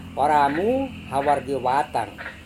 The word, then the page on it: waramu hawar viewatang: Serewen